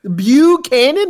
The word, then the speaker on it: Buchanan: Buchanan. That's how you say that word. – Yes, Buchanan.